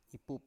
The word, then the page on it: ipup: Biak